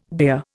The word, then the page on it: beer: Indian